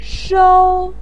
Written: shou1

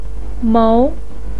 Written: mou2

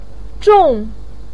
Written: zhong4

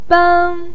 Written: beng1

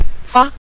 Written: fa5

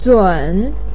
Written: zun3

